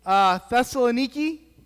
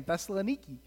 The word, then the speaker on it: Thessalonica: Thessalonica. I'm serious, it's how you say it. Like, I was actually like, that sounds funny. I like saying Thessaloniki.